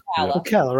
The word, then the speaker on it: Ocala: I think that's pronounced in Ocala. – Ocala, right? Yeah.